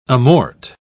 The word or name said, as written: amort